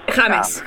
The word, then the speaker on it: James: ¿Cómo se dice, James o James? – James.